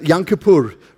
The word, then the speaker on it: Yom Kippur: And then you've got the Hebrew word, which is kapah, the day of Kippur, Yom Kippur. I said it incorrectly. I said it like a Mlungu again, hey? Kippur. It's Yom Kippur, right?